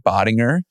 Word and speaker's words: Bodinger: My name is Joey Bodinger. Again, soft G, because we had a little bit of a debate and a lesson before this recording of how to say my last name.